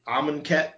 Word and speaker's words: Amonkhet: So, you know, for, is it Amonkhet? Is that how you pronounce the next set? – Yes.